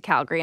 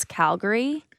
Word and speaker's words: Calgary: It's Calgary, not Calgary. I'm going to help you out.